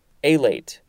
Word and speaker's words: alate: No, alate. This is the first form, A-L-A-T-E. The emphasis is slightly more on the first syllable.